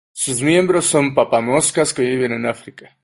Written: papamoscas

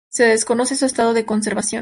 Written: conservación

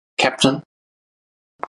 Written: Capt

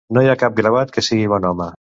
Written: gravat

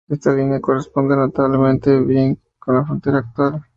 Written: notablemente

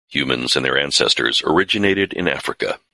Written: ancestors

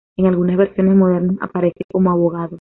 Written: modernas

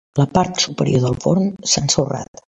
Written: ensorrat

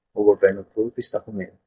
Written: governo

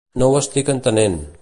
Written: entenent

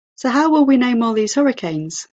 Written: hurricanes

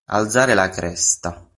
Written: cresta

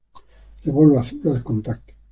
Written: azul

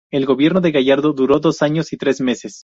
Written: Gallardo

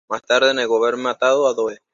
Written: matado

